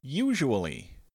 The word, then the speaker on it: usually: Our next word is usually. Usually starts with a long U, then a Z sound, then a schwa sound.